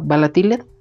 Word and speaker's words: valatiled: todo es arroba balatiled, arroba V-A-L-A-T-I-L-E-D. ¿Lo dije bien? Sí.